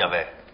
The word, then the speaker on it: Yahweh: I think it's Yahweh, right? – Right, which we don't pronounce. That Yud Heh Vav Heh is a word. – Okay. – No, and it's you'll see it Yahweh or whatever it is. – Okay. – For us, it's an unpronounceable word.